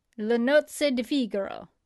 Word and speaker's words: Le Nozze di Figaro: Le Nozze di Figaro is how they pronounce it in the uh, uh, website here.